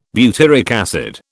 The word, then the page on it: butyric acid: British English